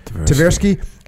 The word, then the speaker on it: Tversky: They were economists Amos Tversky, am I saying that right? – Tversky, and psychologist Daniel Kahneman.